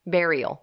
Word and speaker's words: burial: How do you pronounce it? – Burial. – Bear eol. Burial. Burial. – Burial. – I know that. Burial. – Mm-hmm. – You see the word in your head, B U R, burial.